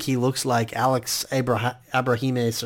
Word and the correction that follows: Abrahantes: or however you say his name. – Abrahantes. – Yeah, Abrahantes.